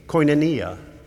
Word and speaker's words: koinonia: There's a Greek word spelled with a K, koinonia.